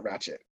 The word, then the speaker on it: Ratched: Is it Ratched or Ratched? – I think it's Ratched, but then it's spelled Ratched. – There's a D, yeah. – There's a D, so it's confusing. – Yeah. – But she pronounces it Ratched.